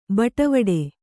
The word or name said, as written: ಬಟ್ವಾಡೆ